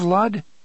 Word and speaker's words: Lud: "Have you heard of Lud?" Eddie asked. – "Perhaps," the gunslinger said, "when I was very small." – "It rhymes with crud," Eddie said.